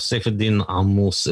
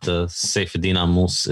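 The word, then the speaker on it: Saifedean Ammous: Saifedean Ahmous is how it's pronounced in Arabic, but Saifedean Ahmous is good enough in English.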